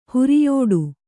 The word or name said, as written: ಹೊರಿಯೊಟ್ಟೆ